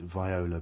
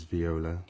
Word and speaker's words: viola: And I'm always tempted to pronounce it viola, but you assure me that it is viola.